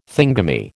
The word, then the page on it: thingummy: American English